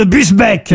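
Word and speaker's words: Busbecq: Ogier Gilin de Busbeck, pardon, j'ai écorché son nom. – Il me semblait bien aussi. – Ça, c'est chou. Ça, oui. Est-ce que vous pouvez appeler Busbeck? – Busbeck. Ogier Gislin de Busbeck. – Ah, Gislin.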